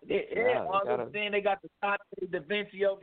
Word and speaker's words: DiVincenzo: It's DiVincenzo. Look, he's so bad. I don't even know how to say his name. It's DiVincenzo.